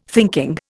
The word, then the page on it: thinking: Indian